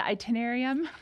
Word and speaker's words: Itinerarium: And how do you pronounce it? The itinerarium? – Itinerarium. Yeah, itinerarium. Journey of the Mind to God.